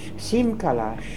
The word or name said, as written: Simkalash